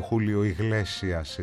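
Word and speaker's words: Julio Iglesias: Μου λέει, πριν προχωρήσουμε όλα τα μηνύματα και τα σχετικά, θυμίσω ότι σαν σήμερα το 1943 γεννήθηκε ο Χούλιο Ιγλέσιας. Έτσι είναι η σωστή προφορά, θα μου λέγε η Μαρία Σφυρόαιρα. Χούλιο Ιγλέσιας τον λέμε εμείς. Αυτός ο τραγουδιστής, συνθέτης, ηθοποιός, πολύ μεγάλη μορφή της τέχνης.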